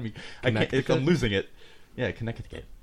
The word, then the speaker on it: Connecticut: Connecticut. – How many T's are in there? – A lot. – There's a silent C.